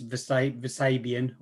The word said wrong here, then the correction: Vespasian: I can never pronounce his name correctly. – Vespasian, I think. – Vespasian, yeah.